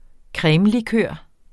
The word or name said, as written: cremelikør